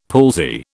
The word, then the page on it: palsy: British English